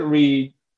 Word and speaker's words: READ: It's spelled R-E-A-D. What's the origin story of the name? – Yeah. So we call it Reed.